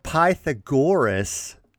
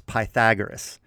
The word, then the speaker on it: Pythagoras: Instead of Pythagoras, it's Pythagoras.